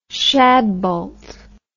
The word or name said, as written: shadbolt